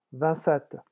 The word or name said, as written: vingt sept